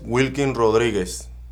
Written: Wilking Rodriguez

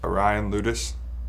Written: Ryan Loutos

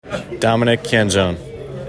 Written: Dominic Canzone

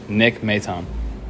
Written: Nick Maton

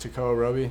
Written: Tekoah Roby

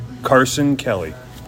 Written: Carson Kelly

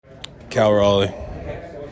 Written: Cal Raleigh

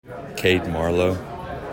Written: Cade Marlowe

